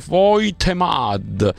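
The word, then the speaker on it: Woltemade: Il tedescone si scrive Voltemad, si legge Voitemad, e Papa Sar si legge Papsar.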